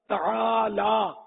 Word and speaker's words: تالا: کوئی تلا بولتا ہے کوئی تالا بولتا ہے، وہ صحیح تلفظ اس کا تالا یہ نہیں بولتے.